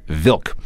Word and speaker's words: Wilk: I know his last name starts with a W, but the proper pronunciation is Vilk.